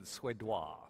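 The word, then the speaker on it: Suedois: Well, I just say what Kurt Becker said, suédois, very French pronunciation for the French bred son of Le Havre.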